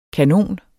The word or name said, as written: kanon